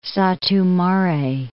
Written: Satu-Mare

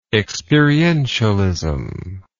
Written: experientialistic

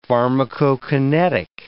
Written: pharmacokinetic

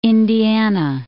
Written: Indiana

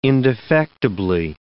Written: indefectibly

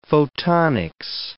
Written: photonics

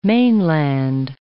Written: mainland

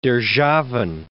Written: Derzhavin

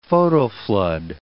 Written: photoflood

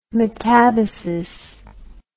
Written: metabasis

